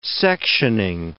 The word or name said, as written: sectioning